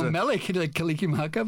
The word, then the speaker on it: Mele Kalikimaka: Something from Willie Kalikimaka. Did I say that right? – Well, I don't know. I'm not Hawaiian, but, okay, but I know Mele Kalikimaka, but yeah.